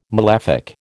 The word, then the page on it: malefic: American English